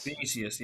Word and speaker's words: Vinicius: Vinicius? – Vinicius, yeah.